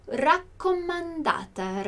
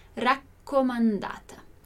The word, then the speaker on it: raccomandata: Então, pacco semplice ou raccomandata, raccomandata. Attenzione alla pronuncia delle due C, né? Aqui a gente tem os nossos dois Cs, raccomandata.